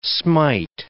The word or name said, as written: smite